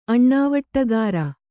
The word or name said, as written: ಅಣ್ಣಾವಟ್ಟಗಾರ